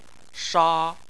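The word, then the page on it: sha: tone 1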